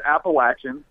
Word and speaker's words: Appalachian: First of all, it's Appalachian. Come on, let's get the name right.